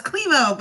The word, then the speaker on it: Klemow: Did I say it right? – So close. Klemow, but Klemow.